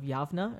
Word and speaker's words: Yavna: Yavna, and that's just two different ways of pronouncing the same city name. – It's spelled Yavna. Like... – It's spelled Yavna, but we say Jamnia.